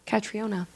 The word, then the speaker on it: Catriona: Really quick, Catriona. – Catriona. – That's how you say it.